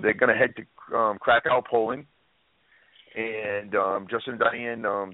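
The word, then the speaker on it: Kraków: And it was titled Krakow. Krakow. Am I saying it right? Krakow. Krakow. – Krakow.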